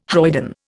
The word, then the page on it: hoyden: Indian